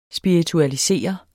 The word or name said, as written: spiritualisere